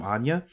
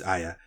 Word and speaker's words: Aya: I was invested in, I think you pronounce her name Anya. Or Aya, it's Aya.